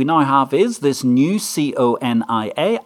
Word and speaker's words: CONIA: I would call that C O N I A, On the grounds that if we don't call it C O N I A and pronounce it absolutely brutally with shorter space, then it sounds like something much, much, much worse than anything Ibor ever brought upon us.